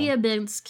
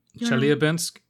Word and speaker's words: Chelyabinsk: Chelyabinsk sounds right. – It does. So I think it's uh, Chelyabinsk and deliver its stunningly improbable cuboid crystal for us to study.